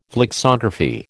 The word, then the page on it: flexography: American English